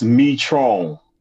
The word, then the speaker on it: Methrone: It's Methrone.